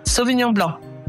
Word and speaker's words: Sauvignon Blanc: Sauvignon Blanc.